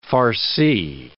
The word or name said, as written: farci